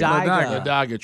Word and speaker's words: Ladiga: Ladaga Trail. – Ladaga Trail. – Because it's spelled Ladiga.